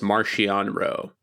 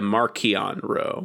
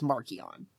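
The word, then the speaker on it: Marchion Ro: I have heard this pronounced Marcian and Markion. – Uh-huh. – Apparently, in the new High Republic book, Mark Thompson adjusts his... – Wow. – Adjusts his pronunciation to Markion Rowe. – I'm not surprised, and the reason I say that is Charles Soule, on multiple occasions, has come out being like, no, it's Markion.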